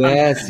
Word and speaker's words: Bouygues: Also ich hätte es wahrscheinlich eher Bouygues ausgesprochen, aber es gibt auf YouTube ein eigenes Video, wo man lernen kann, Bouygues, oder Bouygues ausgesprochen Bouygues werden.